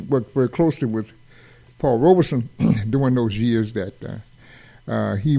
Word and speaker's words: Robeson: And he was particular about you pronouncing his last name right, Robeson. It's R-O-B-E, like a robe, rope, and then son, Robeson.